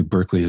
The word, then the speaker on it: Berkeley: One of my favorites is George Berkeley. He's actually who Berkeley is named after. We mispronounce it.